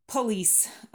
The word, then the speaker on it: police: Police. Дальше по произношению. Police ударение. Police на второй слог.